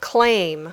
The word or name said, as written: claim